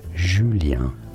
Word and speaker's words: Julien: Well, in French it is said as Julien. – Julien. – So a J sound and then a U sound, which is very French for the letter U.